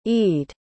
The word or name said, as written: ede